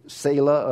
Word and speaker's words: Selah: It's S-E-L-A-H. Anybody remember seeing this? And some people say Selah, others, I've heard it pronounced Selah.